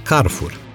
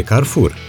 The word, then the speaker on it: Carrefour: Probabil că 3/4 dintre români pronunță Carrefour, cam la fel ca pe car wash, cu accent pe prima silabă în loc de Carrefour, cu accentul pe a doua silabă, cum ar fi corect, dat fiind că este vorba de o companie franceză al cărei cartier general se găsește la Massy.